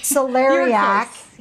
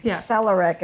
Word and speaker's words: celeriac: Celeriac. So I said it wrong. Okay. Celeriac. Celeric is what I said.